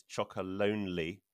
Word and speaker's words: Chocolonely: I know you've had recently some press coverage on the fact that lots of people think that you're called Chocolonely, Tony's Chocolonely, and they just read that name wrong. It's not right. It's Chocolonely.